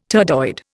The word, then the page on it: turdoid: Indian